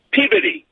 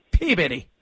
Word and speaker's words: Peabody: And then if you're from Massachusetts and you live near the city of Peabody, it's pronounced Peabody. – Peabody.